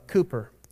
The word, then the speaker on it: Cowper: Some of you maybe have heard of the hymn writer William uh, Cooper, or it's, it's spelled Cowper.